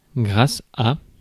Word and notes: grace: /ɡɹeɪs/

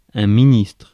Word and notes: minister: /ˈmɪn.ɪ.stə/

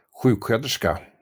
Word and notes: sjuksköterska: /ˈɧʉːkˌɧøːterska/; /ˈɧʉːkˌɧøːtɛrska/

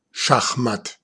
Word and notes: échec et mat: /e.ʃɛk‿e mat/; France (Île-de-France)

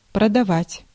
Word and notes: betray: American English; [bɪ.ˈtɹeɪ]

